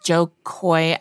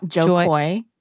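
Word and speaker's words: Jo Koy: Did I say it right? – I think you said it wrong. Joe Coy.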